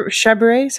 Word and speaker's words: Chabert: How do I pronounce her last name? – Chabert, something like that. – Chabert, that's it.